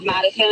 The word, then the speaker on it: Madison: My uh, like legal name is Madison.